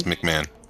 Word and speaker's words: McMahon: Is that how you say it? It's M-A-H-O-N, is that McMahon? Yeah, it's McMahon. – Okay, well, whatever. – Really? – What? I mean, it says M-A-H.